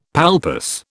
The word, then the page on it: palpus: British English